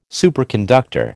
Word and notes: superconductor: American English

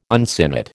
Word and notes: uncinate: American English

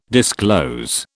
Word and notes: disclose: British English

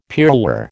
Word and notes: pearlware: American English

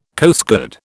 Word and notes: Coastguard: British English

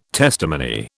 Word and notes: testimony: British English

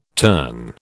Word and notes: terne: British English

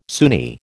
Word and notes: Sunni: American English

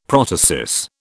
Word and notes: protasis: British English

